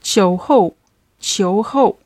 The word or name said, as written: qiuhou